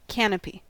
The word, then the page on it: canopy: American English